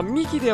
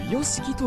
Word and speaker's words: ヨシキ: あ、でもさくらさん、これはミキではなくヨシキと読むんですが。